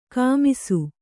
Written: ಕುಮ್ಮಿಸು

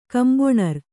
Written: ಕಂಬೊಣರ್

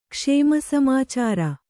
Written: ಕ್ಷೇಮಸಮಾಚಾರ

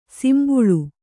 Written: ಸಿಂಬುಳು

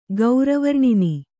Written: ಗೌರವರ್ಣಿನಿ

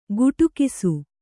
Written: ಗುಟುಕಿಸು

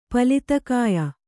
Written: ಪಲಿತಕಾಯ

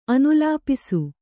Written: ಅನುಲೇಪಿಸು

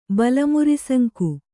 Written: ಬಲಮುರಿಸಂಕು

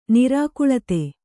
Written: ನಿರಾಕುಲತೆ

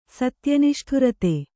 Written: ಸತ್ಯನಿಷ್ಠುರತೆ

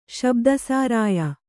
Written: ಶಬ್ದಸಾರಾಯ

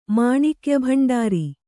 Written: ಮಾಣಿಕ್ಯಭಂಡಾರಿ

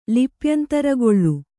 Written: ಲಿಪ್ಯಂತರಗೊಳ್ಳು

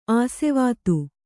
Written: ಆಸ್ವಾದ

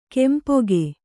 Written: ಕೆಂಪೊಗೆ

